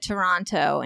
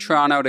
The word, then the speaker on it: Toronto: Well, he's got a bit of that northern thing because it's just Toronto to Canadians because that's just how we say it. But a lot of Americans say Toronto and it's like, Yeah.